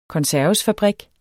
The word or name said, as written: konservesfabrik